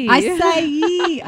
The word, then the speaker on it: acai: Is it I-C-E? Akai? How do you say that? – Acai. – I would have said acai.